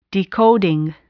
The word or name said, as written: decoding